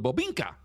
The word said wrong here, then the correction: Boginka: Nope, you said that wrong. – Boginka. – Yeah, there you go.